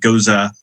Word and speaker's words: Gose: So that is the German Goza. It's spelled G-O-S-E.